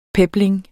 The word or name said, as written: pebling